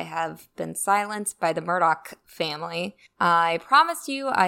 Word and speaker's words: Murdaugh: So first of all, the name is pronounced Murdoch, not Murdaw. If you hear a reporter saying the name without the k at the end, they're basically admitting to having no sources in Hampton County and not knowing what they're talking about.